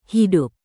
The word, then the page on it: hidup: indonesian